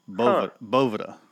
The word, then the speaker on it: Boveda: The only reason I know that is if you watch their videos on their website, that's uh-huh. how they pronounce it. Boveda.